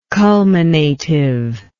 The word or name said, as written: culminative